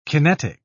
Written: kinetic

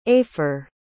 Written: aefre

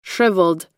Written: shriveled

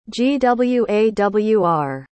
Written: gwawr

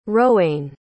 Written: rowanne

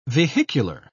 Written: vehicular

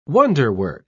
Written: wonderwork

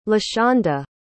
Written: lashonda

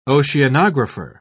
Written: oceanographer

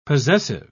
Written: possessive

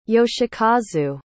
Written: yoshikazu

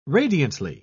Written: radiantly

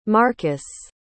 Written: marcus

